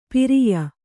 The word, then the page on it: ಪಿರಿಯ: kannada